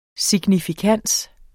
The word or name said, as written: SIGNIFIKANSEN